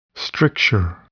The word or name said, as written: stricture